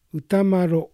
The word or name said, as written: Utamaro